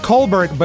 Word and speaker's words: Colbert: The lady said it's pronounced Colbert, but sometimes to sound classy, locals say Colbert. But it Colbert. Is Colbert.